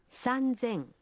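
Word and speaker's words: Санзен (三千): Но и здесь произношение меняется. 3000 будет звучать как… «Санзен».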